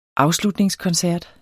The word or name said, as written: afslutningskoncert